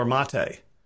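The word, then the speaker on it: Maté: His last name is spelled M-A-T-E, like checkmate.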